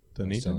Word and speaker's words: Dunedin: Dunedin. That's how it's pronounced.